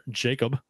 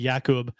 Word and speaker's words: Jakub: So we'll kind of talk about how everyone pronounces his name, Jakub or Jacob Verana. He says both are fine. It's actually Jakub, but both are fine.